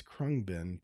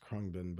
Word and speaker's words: Khruangbin: the band is Khruangbin, pronounced Khruangbin, but it's spelled K H R U A N G B I N, Khruangbin.